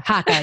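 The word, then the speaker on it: Haakaa: Is that how you say it? Haka? – I think it's Haka. I don't really Haka. – Know. – Haka. Yes. Haka. I was making it fancy.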